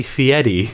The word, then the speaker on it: Fieri: It's like Guy Fieri. And she says fiery. – Fieri. – And it's actually Fieri. – It's fiery.